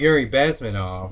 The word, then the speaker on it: Bezmenov: Yuri Bezmenov.